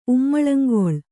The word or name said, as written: ಉಮ್ಮಳಂಗೊಳ್